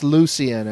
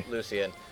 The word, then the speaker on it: Lucian: What has Lucian Dodge done in the past? I don't recognize that name. – I think it's pronounced Lucian, actually. – Lucian.